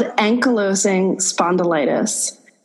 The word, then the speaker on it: ankylosing spondylitis: So it's called ankylosing spondylitis.